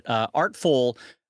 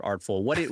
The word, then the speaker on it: Artfol: So, Brad, switching topics, I wanted to ask you, because I know you had a lot of thoughts on it, Artful, the new social media platform specifically for artists. For those that are not familiar with it, it's spelled Artfol, which I guess is short for Artfolio.